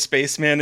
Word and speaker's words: Spaceman: And his name actually is Spaceman. It's just pronounced Spaceman.